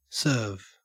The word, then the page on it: serve: Australian English